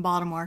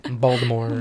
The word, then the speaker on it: Baltimore: See, I can tell you're not from Baltimore because you say it wrong. – What, Oliver? – Baltimore. – Yeah, there's a T in Baltimore.